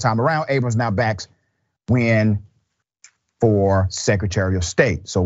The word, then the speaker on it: Nguyen: Nguyen. It is spelled N-G-U-Y-E-N, but pronounced Nguyen.